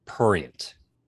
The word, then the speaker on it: prurient: You guys kept saying the word prurient. – Yeah. – Yeah. – It's pronounced prurient. There's an R in there.